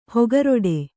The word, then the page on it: ಹೊಗರಿಡು: Kannada